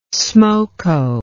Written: smoko

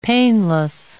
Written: painless

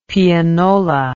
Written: pianola

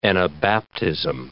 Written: anabaptism

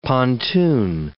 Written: pontoon

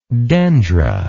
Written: dendra

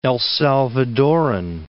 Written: El Salvadoran

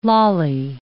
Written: lolly

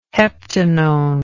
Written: heptanone

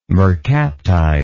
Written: mercaptide

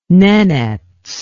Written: nenets

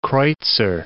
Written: kreuzer

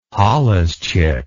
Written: holluschick

